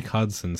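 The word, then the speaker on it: Khaleke Hudson: Kalicki Hudson, Cody Barton, and Jamin Davis in their inside linebacker core. To me, this is pretty easy. – Kalik Hudson. Say it right, bro.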